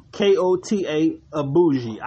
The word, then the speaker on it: Kota Ibushi: Koto Ibushi? – That's about right. – Man, I hope that's right. I'm not sure. Let's see here. Yeah, Koto, Kota Ibushi, Ibushi.